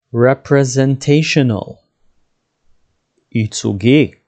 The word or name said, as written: representational